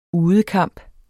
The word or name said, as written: udekamp